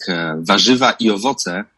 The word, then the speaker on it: warzywa i owoce: Original title in Polish sounds like warzywa i owoce.